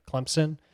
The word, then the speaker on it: Clemson: And he's like, yeah, it's Clemson. – Oh, that was good. – Clemson. – Yes. – And he's like, Clemson. He's like, Clemson. He's like, yeah. There's no P. The P is silent.